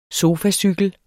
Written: sofacykel

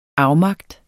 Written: afmagt

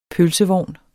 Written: pølsevogn

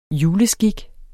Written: juleskik

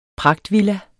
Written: pragtvilla

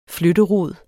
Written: flytterod

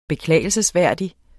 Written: beklagelsesværdig

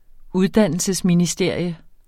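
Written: uddannelsesministerium

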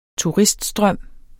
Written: turiststrøm

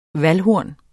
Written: VALDHORNET

